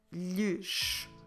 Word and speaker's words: lhes: So, como se escreve lhes? L-H-E-S.